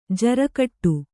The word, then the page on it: ಜಾರುಗಾಡಿ: jāru gāḍi